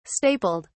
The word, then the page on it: stapled: British English